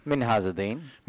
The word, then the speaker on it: Minhazuddin: Minhazuddin.